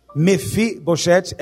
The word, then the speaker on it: Mefiboshet: Mefiboshet é como se pronuncia em hebraico. Mefiboshet em português.